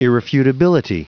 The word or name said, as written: irrefutability